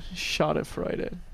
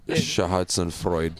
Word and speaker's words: Schadenfreude: Yeah, it's the Schadenfreude. – The what? – There's a German word for it. – Yeah, what was it again? – Schadenfreude.